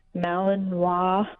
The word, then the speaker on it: Malinois: And so we did take a look at a couple of Belgian Malinois, I hope I'm saying that right, dogs.